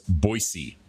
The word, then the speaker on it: Boise: Actually, it's pronounced Boise, by the way.